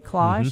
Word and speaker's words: cloche: And so for indoor gardening, a cloche, did I say it right? Cloche, mm-hmm. – cloche, yeah. And moisture meter are two really good things to have, and light.